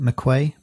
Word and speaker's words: McHugh: He's like, is it Ian how do you pronounce it? – McHugh.